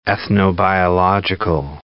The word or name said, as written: ethnobiological